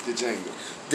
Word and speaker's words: Django: Nigga said the Django. The D is silent. – The D is for these nuts. – The Django.